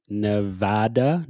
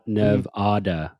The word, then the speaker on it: Nevada: Yeah, apparently the only acceptable pronunciation of the state is Nevada, not Nevada.